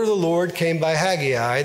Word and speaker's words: Haggai: The correct pronunciation is Haggai. If I pronounce it as Haggai at some point during the message, please forgive me because I am Southern, and it looks like Haggai to me, even though it's pronounced Haggai.